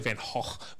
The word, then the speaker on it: Van Gogh: It's actually Van Gogh, but whatever, you know, we're not all Dutch.